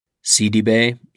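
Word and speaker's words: Sidibe: Seedy Bay sang on this episode as well, and you can find Seedy Bay's music on Spotify.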